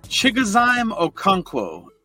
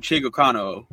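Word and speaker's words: Chigoziem Okonkwo: It's Chigozime Okonkwo, but I like to call him Chigokano.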